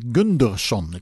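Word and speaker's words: Gundersen: En dit was de versie van Inge-Marie Gudmundsen. Ja, Inge-Marie Gunderson. Ik moet het wel even goed uitspreken uit Scandinavië komt ze, dat is duidelijk.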